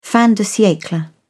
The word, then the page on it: fin de siecle: British English